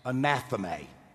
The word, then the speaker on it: anathema: It's pronounced anathema. Anathema.